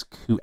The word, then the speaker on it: Kuechly: It's Koo, actually.